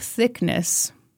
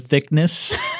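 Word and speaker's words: Thicknesse: One dude who came to see it was Philip Thickness.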